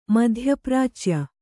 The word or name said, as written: ಮಧ್ಯಪ್ರಾಚ್ಯ